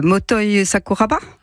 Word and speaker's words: Motoi Sakuraba: Alors, je pense à une anecdote avec un musicien, Motoi Sakuraba. – Oui. – Bien prononcé. ça me fait plaisir.